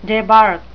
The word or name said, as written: debark